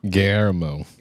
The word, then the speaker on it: Guillermo: Guillermo. – Yes. Guillermo.